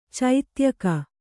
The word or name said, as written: ಚೈತ್ಯಕ